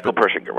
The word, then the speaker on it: Persinger: Isn't there, and help me if I mispronounce the name, isn't there a doctor, I think it's Michael Persinger? – Persinger, right.